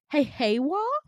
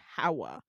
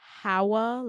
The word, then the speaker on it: Hawa: Your name is Heiwa? No, it's pronounced Hawa. Sorry, can you say that again? Hawa, like ha ha ha, wa, Hawa.